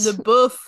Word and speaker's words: LaBeouf: Now look, I don't know anything about anything, but I'm like 99% sure it's not supposed to be pronounced LaBeouf. And they spelled it LABEEF.